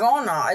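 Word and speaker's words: Wernher: Werner. It actually starts with a W, but the way that they pronounce it.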